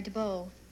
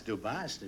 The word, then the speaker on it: DuBost: D-U-B-O-S-T, DuBost. Isn't it? Why don't you pronounce the S? – The S is silent, sir. – Well, why is it silent? It's DuBost. It's no, it's pronounced DuBost.